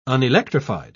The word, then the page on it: unelectrified: British English